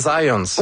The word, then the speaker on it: Zajonc: Non, c'est Zajonc. – Ouais, OK. – J'ai-tu pas dit Zajonc? – Vous avez dit plus Zajonc, mais... – Parce que j'ai prononcé un petit peu à l'anglais. J'ai prononcé mon polonais à l'anglais.